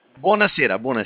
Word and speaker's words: buonasera: Buonasera. – Buonasera.